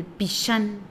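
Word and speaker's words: Pixán: Y es una lengua maya que cuando se pronuncia, para empezar se escribe con H y con X, el Pishán, y se pronuncia Hanal, y Hanal es comida, y Pishán es alma que da la vida al cuerpo.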